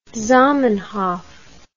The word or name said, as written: zamenhof